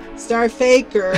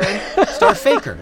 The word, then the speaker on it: STRFKR: I can't really say the name of it, but it's spelled S-T-R-F-K-R, star, effer. – Starfaker. – Starfaker. It's Starfaker, right.